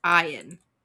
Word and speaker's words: Ian: And his name is spelled Ian, but it's pronounced Ian.